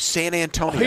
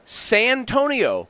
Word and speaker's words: Santonio: pronounces his name San Antonio. – Here's what I can't understand. The actual city San Antonio, S-A-N, space, capital A, his name has none of that. It's Santonio. I'm surprised people don't call him San...